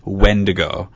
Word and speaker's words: Wendigo: One is the Windigo, and the other is the Wendigo. Oh. One is spelled W-I-N, and one is spelled W-E-N.